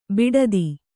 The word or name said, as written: ಬಿಡತ